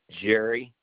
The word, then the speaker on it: Jerry: Jerry Harbison, H-A-R-B-E-S-O-N. – And is Jerry spelled J-E-R-R-Y? – That's correct.